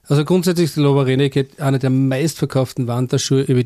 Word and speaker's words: Lowa Renegade: aber ist schon der Lowa Renegade, heißt das so? Ja. Spricht man das richtig ganz aus? Genau.